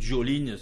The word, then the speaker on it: Jolene: Mais on va quand même, et en souvenir de Dolly Parton, écouter Jolene ou Jolene, si on prononce en anglais.